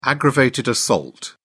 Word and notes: aggravated assault: British English

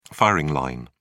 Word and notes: firing line: British English

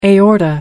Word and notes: aorta: American English

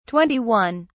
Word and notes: 21: British English

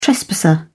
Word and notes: trespasser: British English

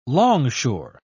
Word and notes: longshore: British English

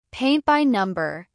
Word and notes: paint by number: American English

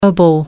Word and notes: ible: American English

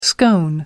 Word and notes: scone: American English

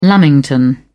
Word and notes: lamington: British English

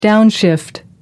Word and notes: downshift: American English

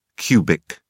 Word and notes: cu: British English

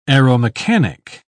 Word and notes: aeromechanic: British English

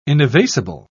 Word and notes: inevasible: British English

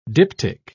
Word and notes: diptych: British English

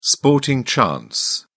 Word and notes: sporting chance: British English

